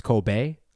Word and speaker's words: Kobe: Yeah, it's actually pronounced Kobe, but oh, nice.